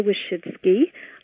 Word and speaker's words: Wyszydzki: Hi, my name is Frida Wyszydzki. My name is spelled like the three little words, Wish and Ski.